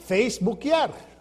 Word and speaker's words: Feisbuquear: And the way they spelled it is Feisbuquear. And they pronounce it Facebookiar.